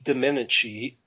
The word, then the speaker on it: Domenici: One of these early letters went to Senator Pete Domenici. That's how you pronounce the name.